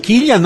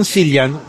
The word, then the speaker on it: Cillian: Ma dai, Cillian, non Cillian, ma tutta la vita a sbagliare noi siamo stati.